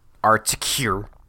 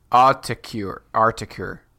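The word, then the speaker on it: Autechre: Yeah, so specifically, Thom Yorke mentions Aphex Twin and Articure. Mm-hmm. I don't know how to say that, dude. Let's figure it out, man. Pronunciation. Articure. – You were close.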